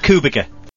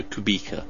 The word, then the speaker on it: Kubica: Not Kubica. – Emphasis again, Robert. On the middle syllable, so Kubica.